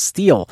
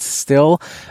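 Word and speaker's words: Stihl: Which is kind of odd, because I've always thought it was still, but I've actually heard on ads on TV it being pronounced steel. So it's kind of interesting that there's such a discrepancy there. But they confirmed in the booth that it is pronounced still.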